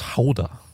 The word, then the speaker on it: Gouda: I say, apparently it's pronounced Hauda. Like Hauda. That's how you say it.